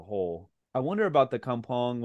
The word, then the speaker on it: Kampong: Kampong, am I saying that correctly? – Kampong, yeah.